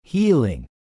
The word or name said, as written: healing